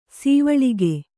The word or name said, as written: ಸವಲಕ್ಕ